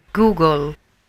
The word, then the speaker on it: Google: Mi dica, noi abbiamo la pronuncia esatta, perché l'abbiamo presa Gogol.